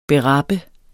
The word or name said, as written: berappe